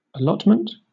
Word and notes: allotment: British English

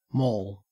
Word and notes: mall: Australian English

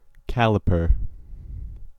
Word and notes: caliper: American English

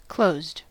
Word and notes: closed: American English

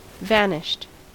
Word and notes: vanished: American English

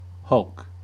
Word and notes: hulk: American English

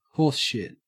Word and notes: horseshit: Australian English

